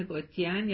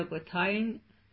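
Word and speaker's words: Gothein: یکی از محققینی که خیلی کتاب با ارزشی توی زمینه تاریخ باغ‌سازی از قالب هنر یعنی گاردن آرت نوشته، خانم گوتین یا گوتاین اگه تلفظ اصلی اسمش رو بخوایم، هست که در 1913 کتابی نوشته به نام History of Garden Art.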